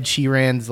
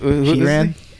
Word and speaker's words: Sheeran: Yeah, like Ed Sheeran's, like the name of his albums are like plus Ed what? – Sheeran.